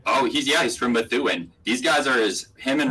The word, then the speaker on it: Methuen: God, from Methuen too. And like some excellent pronunciation of Methuen. I feel like a lot of people don't nail that. That was really good.